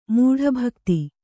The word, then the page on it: ಮೂಢಭಕ್ತಿ: Kannada